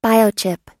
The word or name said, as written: biochip